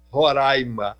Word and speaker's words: Roraima: Venha conhecer, você não vai se arrepender de conhecer Roraima. Aliás, a gente aqui é bairrista ao pedir que as pessoas pronunciem Roraima e não Roraima.